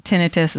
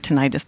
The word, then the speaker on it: tinnitus: They do say that tinnitus is what it's called, or tinnitus, depending on whatever. They're both correct pronunciations, but they will say oftentimes it's the cry of the hair cells in your inner ear in distress.